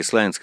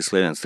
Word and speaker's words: Славянск: Местные люди, как Славянск, оба правильные, Славянск и Славянск.